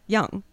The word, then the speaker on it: Yong: Thanks for emphasizing the Yong. – Yeah. Did I pronounce it right? – No, because when you say, and when I say it quickly, it's just like Yong. And then everyone misspells it Y-O-U-N-G.